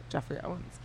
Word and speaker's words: Geoffrey Owens: Jeffrey Owens.